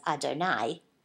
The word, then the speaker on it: Adonai: And hence, the Torah adds the tetragrammaton, the four-letter name of God, which we pronounce as Adonai, which is the traditional designation of God's mercy, in chapter 2.